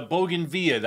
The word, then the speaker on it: bougainvillea: Am I saying it right, guys? Bougainvillea? – I think so. That's how I've always said it.